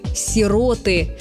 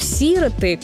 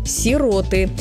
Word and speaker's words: сироты: Правильный вариант только такой – «сироты». Ударение на первый слог «сироты», которое порой встречается не только в разговорной речи, но и в официальных выступлениях, звучит на радио или в телевизионных ток-шоу неверное. Словари разрешают ударение только на второй слог – «сироты». Во всех падежах «сирот», «сиротам», «о сиротах». Многие словари специально уточняют «не сироты».